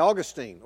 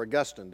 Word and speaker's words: Augustine: formulated by Augustine, or Augustine, depending on how you want to pronounce his name.